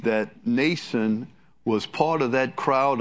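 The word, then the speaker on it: Mnason: Nason is a Greek word, a Greek name, and the M is silent. So it's pronounced Nason.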